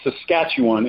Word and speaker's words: Saskatchewan: And it's pronounced Saskatchewan is the province, the western province in Canada, where I reside with my family.